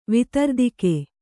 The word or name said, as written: ವಿತರ್ದಿಕೆ